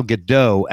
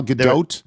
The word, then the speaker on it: Gadot: And I found out the correct pronunciation for that actress, it's not Gal Gadot, as I thought it was. It's Gal right. Gadot. – Gal Gadot? – Yes. – There's a hard T in that, Gal Gadot?